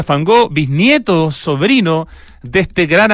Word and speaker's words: van Gogh: Soy Willem van Gogh, como se pronuncia en holandés. – Ah, Van Gogh. No había, nunca había escuchado ese j.